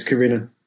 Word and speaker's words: Karina: I mean, the ma- the main story that kind of the connecting story um, is is it Karina or Karina? Do you know how you pronounce her name? – Yeah, it's Karina.